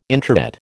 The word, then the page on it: introit: American English